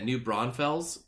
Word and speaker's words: New Braunfels: New Braunfels, Texas, right? – Yep.